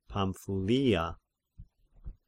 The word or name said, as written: Pamphylia